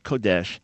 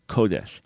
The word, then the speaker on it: Kodesh: Maybe it's mispronounced in your synagogue, but Kodesh is accent on the first syllable, Kodesh, not Kodesh, Kodesh.